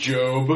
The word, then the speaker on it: Gob: G O B. – That's it. – Gob. – Thank God. – Gob. – It's pronounced Job.